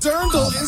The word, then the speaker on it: Dirndl: I called it a dreidel. – yeah, not a dreidel. – It's drindel. No, Dirndl is the correct answer.